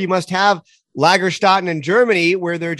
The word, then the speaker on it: Lagerstätte: I maybe I'm going to mispronounce this, but it's the word Lagerstadt. Did I did I say that right? – Yeah, the German word would be Lagerstätten or Lagerstätte in singular, which is a, a fossil locality with special preservational circumstances yeah, those are supposed to be huge localities of fossils.